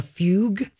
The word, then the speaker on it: fugue: fugue state. Mm-hmm. Am I saying that right? – I think so, yeah. – F-U-G-U-E.